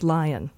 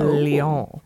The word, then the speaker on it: Lyon: it's not pronounced lion. Wait, um, L E O N? L Y. Oh, oh, oh. Lyon. I like it, yeah.